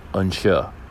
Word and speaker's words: Anseo: It's an amazing bar called Unsure, which is spelled A-N-S-E-O, and is the Irish word for here.